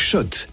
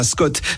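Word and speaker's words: Schott: Sur RMC avec Shot, la marque iconique new-yorkaise depuis 1913. Oui, Shot et pas Scott. Ça fait des années qu'on prononce mal la marque.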